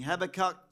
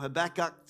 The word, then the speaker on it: Habakkuk: Okay, the scripture that I believe is appropriate for each one of us in this season of fasting, Habakkuk 2, or Habakkuk 2, depending on which pronunciation you like.